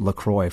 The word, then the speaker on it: LaCroix: If you don't know, LaCroix sparkling water, pronounced LaCroix for anybody not pronouncing it correctly, delivers refreshment, flavor, and sparkle with an innocent twist of zero calories, zero sweeteners, and zero sodium.